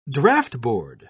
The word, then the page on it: draughtboard: British English